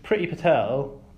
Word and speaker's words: Priti Patel: So, Priti Patel...